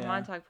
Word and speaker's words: Montauk: Was it the Montauk? Am I saying that right? – Oh, yeah. Montauk Project.